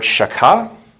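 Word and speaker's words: shachah: Then you have the Hebrew word shakah, if you're pronouncing it the way, you know, the good old-fashioned Hebrews would. It's that guttural, it's that shakah kind of a thing. S-H-A-C-H-A-H. S-H-A-C-H-A-H.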